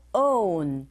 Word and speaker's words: own: Islands have their own, on이 아니고 own. 동그라미 동그랗게 입을 오므려서 발음하시면 됩니다.